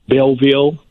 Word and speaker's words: Belleville: We have a school way down in Belleville.